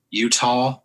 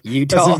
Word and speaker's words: Eutaw: Burnt Corn, Utah, but it's spelled E-U-T-A-W. – Mm. Oh. – Utah. – Yeah. – That's Utah. That's a very Alabama way to spell Utah.